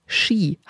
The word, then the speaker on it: Qi: Die Akupunktur soll den Fluss der Lebensenergie regulieren, dem sogenannten Qi. Habe ich es richtig ausgesprochen? – Richtig, wie Skifahren. – Ja, nur, dass man es anders schreibt mit QI.